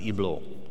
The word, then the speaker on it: Hublot: I was reading about this luxury watchmaker, Switzerland watchmaker, called, it's pronounced Hublot.